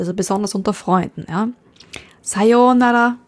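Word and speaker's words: Sayonara (さようなら): Vorsicht bei der Aussprache, also nicht Sayonara, nicht auf dem Nara, sondern auf dem Sayonara. Sayonara.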